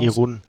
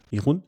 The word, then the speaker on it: Irun: Route, Etappe Nummer 5 nach Bilbao von, ich sage mutmaßlich Irun, spricht man es aus? – Irun. – Irun.